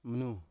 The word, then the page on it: mnu: Biak